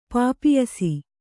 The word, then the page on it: ಪಾಪಿಯಸಿ: Kannada